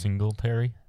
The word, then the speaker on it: Singletary: Singletary.